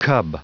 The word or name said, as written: cub